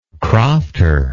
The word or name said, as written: crofter